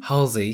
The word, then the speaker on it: Halsey: Halsey, yes.